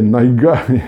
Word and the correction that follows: Niagara: Niagara, już zostawię tą wymowę, e, Niagara jest największy z wodospadów i gwiazd 50 moja flaga ma.